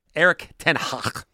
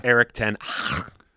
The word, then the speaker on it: Erik ten Hag: Erik Ten Hag. That's how you pronounce his name. Yeah, gotcha. Erik Ten Hag.